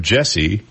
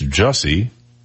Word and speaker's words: Jussie: Everybody calls him Jesse, but it's Jussie.